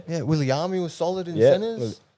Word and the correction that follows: Viliami: I think it's pronounced Viliami.